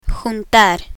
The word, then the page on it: juntar: spanish